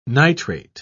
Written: nitrate

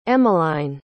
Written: emmeline

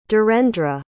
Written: dhirendra

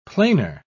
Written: planar

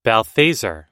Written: balthazar

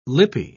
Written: lippy

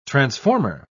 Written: transformer

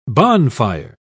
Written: bonfire

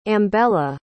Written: ambella

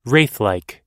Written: wraithlike

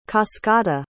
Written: cascata

